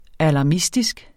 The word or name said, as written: alarmistisk